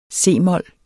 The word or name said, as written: c-mol